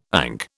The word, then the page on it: ANC: British English